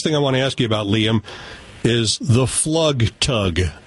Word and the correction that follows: Flugtag: That's a Flugtag, yeah. – A Flugtag. Excuse, I didn't know the German pronunciation of this.